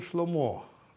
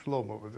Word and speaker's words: Шломо: Вообще говоря, в еврейском языке это называется Соломон Шломо. Но почему-то вот сейчас произносит Шломо. Доктор, который лечит Шарона, почему-то Шломо. По-моему, это странно, потому что Шломо – это мир, по ударению на последнем слоге.